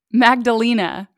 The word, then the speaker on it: Magdalena: So I actually looked up the pronunciation of this. – Hey, tell me. – It's a Magdalena.